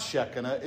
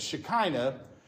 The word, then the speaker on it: Shekinah: Yeah, it's putting the wrong emphasis on the wrong syllable, right? So it's not Shekinah, it's Shekinah. But through the whole 45-minute lesson, not one person of the members of the congregation that were in the Bible study corrected me.